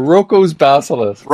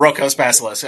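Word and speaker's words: Roko's Basilisk: if I pronounce this right, Rocco's Basilisk? – Yeah, Rocco's Basilisk. – Rocco's Basilisk.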